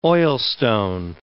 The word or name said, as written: oilstone